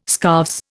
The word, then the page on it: scarves: Indian